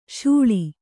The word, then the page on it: ಶಾಳ: Kannada